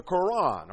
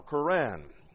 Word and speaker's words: Quran: Their book is called, that is, their religious book is called the Quran or Quran. Both are used in pronunciation.